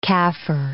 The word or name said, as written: Kafir